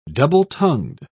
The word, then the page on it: double-tongued: British English